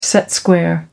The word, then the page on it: set square: American English